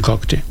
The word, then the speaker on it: Cacti: Där hörte vi ju Kakti. Jag klarar inte uttala den. C-A-C-T-I. – Ja, det hörtes ju som Kakti.